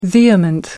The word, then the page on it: vehement: American English